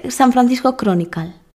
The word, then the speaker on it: San Francisco Chronicle: Se dice San Francisco Chronicle.